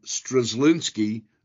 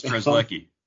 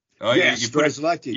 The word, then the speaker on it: Strzelecki: Since the season started, you know, they've added Avila, Parsons, Zach Kent, and Peter Strzelecki, I think I said that right. – Almost, it's Strzelecki. – Strzelecki. – You put a phantom N in there.